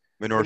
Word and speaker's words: Minoru: Like literally, Morinu says, I think I'm not pronouncing it. How do you guys pronounce his name, guys? – Minoru Suzuki.